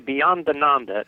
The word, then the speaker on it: Beyondananda: I think I said that right. The Cosmic Comic. – It's actually Bhiyanananda. It's an Italian name.